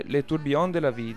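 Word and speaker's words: Le Tourbillon de la vie: Attenzione alla pronuncia francese, Le Tourbillons de la Vie, giusto? – Sì.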